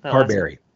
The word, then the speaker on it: Carbary: Carbary.